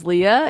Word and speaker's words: Leah: But like I said, my name's Leah.